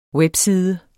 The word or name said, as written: webside